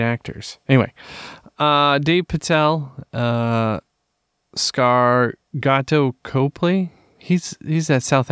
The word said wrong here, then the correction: Dev Patel: Almost as bad as Dev Patel, not Dave Patel, but it's okay. – Oh yeah, Dev Patel.